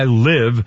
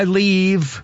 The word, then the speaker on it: live: How Do I Live, which she pronounced How Do I Leave. I mean, it drives me nuts. How do I leave? It's not leave, it's live.